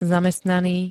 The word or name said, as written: zamestnaný